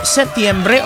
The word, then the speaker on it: Setiembre: What is the proper way to say it? – Setiembre? – Setiembre or Septiembre? – Setiembre. – Oh. – Is it not Septiembre? – Do you know the song?